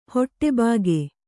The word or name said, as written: ಹೊಟ್ಟೆಬಾಕಿ